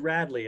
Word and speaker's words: Radlee: Is it Radley? – It's called Radley, and it's spelled R-A-D-L-E-E.